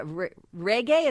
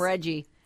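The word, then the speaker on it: Regé-Jean: Yeah, Reggae? Is that how you say his name? – I was watching a couple interviews. Calling him Reggie. – That's how they say his name. And I'm like, it's not spelled like that.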